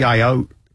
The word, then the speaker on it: Ayotte: New Hampshire, Kelly Ayotte.